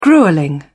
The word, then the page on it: gruelling: British English